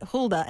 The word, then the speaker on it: Huldah: That's the way you say it, Huldah. And how do you spell it? Would be H-U-L-D-A-H.